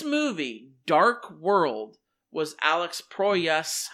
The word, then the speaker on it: Proyas: The director of this film, Alex Poyos. I don't know if I'm pronouncing his name wrong. This is what I was so shocked about. And what Proyas? Proyas.